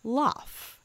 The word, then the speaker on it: l'œuf: And the French word for egg is l'œuf, the pronunciation of which isn't loaf. Too far. – Yeah. – L'œuf, which is not that far off from love.